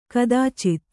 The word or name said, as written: ಕದಾಚಿತ್